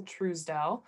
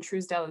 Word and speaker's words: Truesdell: My Facebook's just my name, Ann Truesdell. And on Instagram. – Well, wait, spell that. – Yeah. Good point. A-N-N, and then Truesdell is T-R-U-E-S-D-E-L-L.